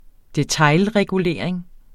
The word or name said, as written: detailregulering